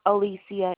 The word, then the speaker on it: Alecia: Alicia is spelled A-L-E-C-I-A. Last name Bruce, B-R-U-C-E.